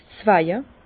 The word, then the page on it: 's Zweje: Bas Rhin; Herrlisheim